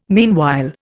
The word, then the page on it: meanwhile: Indian